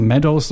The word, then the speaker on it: Meadows: Und äh, Meadows, nicht Midos, wie ich gesagt habe, sondern Meadows, so spricht man es richtig aus.